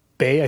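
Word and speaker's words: Weil: Simon, it's Bay. I think it's actually how it's pronounced in Alsatian, but it's W-E-I-L.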